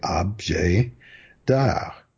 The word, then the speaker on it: objet d'art: And French people say objet d'art with the B. Ob is the first syllable, not objet d'art.